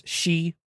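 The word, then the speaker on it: she (四): sounds four is pronounced she, which is the same as the word for death.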